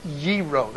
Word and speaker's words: gyro: People say gyro. It's not. Trust me. It's this. It's there's our long O again. It's gyro. They don't have a G sound in the Greek. It's gyro, not gyro.